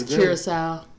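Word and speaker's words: Curaçao: That Blue Caraco. – The what? – Blue Caraco. – I'm pretty sure that's not how you say it. Shit. About I think it's Curacao.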